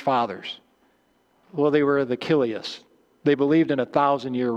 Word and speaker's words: Chiliasts: But almost all of the early church followers were what we call Kilius, C-H-I-L-I-A-S-T-S, but the C-H is pronounced with a K sound, Kilius.